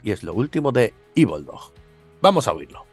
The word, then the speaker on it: Evildog: Pero mientras, aquí tenemos lo último de Evil Dog. Bueno, le decimos Evil Dog porque es la manera correcta de decirlo, pero se escribe Evil Dog, ¿vale?